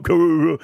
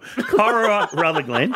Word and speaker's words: Corowa Rutherglen: Corowa Rutherglen. – Say it properly. Not Corowa. Corowa Rutherglen. – I still didn't get it right.